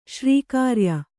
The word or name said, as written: ಶ್ರೀಕಾರ್ಯ